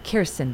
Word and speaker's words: Kirsten: So this dude, right, is hitting on Kristen Dunst. Kirsten Dunst. I always say it wrong.